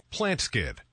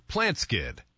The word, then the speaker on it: PlantSkydd: Plant Skid. That's spelled plant, S-K-Y-D-D. Plant Skid. – Again, Plantskid, PlantSkydd.